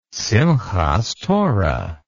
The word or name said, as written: simhath torah